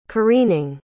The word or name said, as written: careening